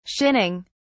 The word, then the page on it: shinning: British English